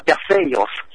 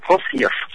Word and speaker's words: Perseus: Chàng dũng sĩ tên là Perseus, tiếng Hy Lạp là Perseus, tiếng Pháp là Perse, còn tiếng Anh nó đọc là Perseus.